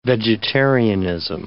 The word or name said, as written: vegetarianism